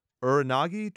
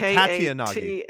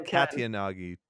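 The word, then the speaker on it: Katayanagi: And he asked for a list, and she ends up giving him a list, and the next people are the Uranagi twins? Is that how you say it? – Oof. It's the Katyanagi. – Yeah, there it is. – Yeah. It's Kyle and Ken, the Katyanagi twins, and then Gideon.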